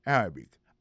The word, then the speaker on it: Arabic: Prononciation correcte, c'est Arabic.